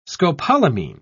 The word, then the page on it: scopolamine: British English